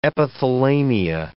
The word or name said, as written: epithalamia